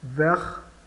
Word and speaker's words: vert: Vert, vert, o T final é mudo, verde.